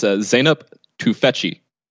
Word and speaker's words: Zeynep Tufekci: So it's Zeynep Tufekci.